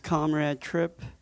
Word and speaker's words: Comrade Tripp: So if you want to adjust your volume as well, my full name is Comrade Tripp. Spelled Comradetripp. The E at the end of my first name is silent, and the second P in my last name doesn't matter either.